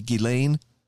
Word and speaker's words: Ghislaine: I want to say Ghislaine Maxwell. I think the S is silent.